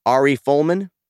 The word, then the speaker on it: Ari Folman: But this is a film directed by, I'm going to butcher the name, Ari Folman. Yes? – Oh, that's right. Yeah, you got it.